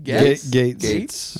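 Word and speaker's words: Gaetz: Gates.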